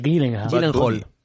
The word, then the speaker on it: Gyllenhaal: Gillingham. Jake Gyllenhaal. – Gyllenhaal. Es Gyllenhaal, ¿no? – El niño burbuja, tío. – ¿Cómo se pronuncia ese apellido, tú? – Gyllenhaal, creo que es.